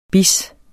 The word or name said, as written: bis